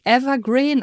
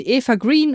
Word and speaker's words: Eva Green: Die spricht man eigentlich Evergreen aus. – Okay. – Aber das klingt so falsch, deshalb sage ich weiterhin Eva Green, obwohl ich weiß, dass die nicht so ausgesprochen wird.